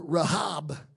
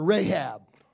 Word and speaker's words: Rahab: We don't call her Rahab. We call her Rahab. So I will use the more common pronunciation tonight to reinforce my point.